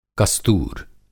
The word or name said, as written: कस्तूर